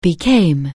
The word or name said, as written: become